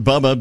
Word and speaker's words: Bubba: John, J-O-N, John. – I'm Bubba, B-U-B-B-A.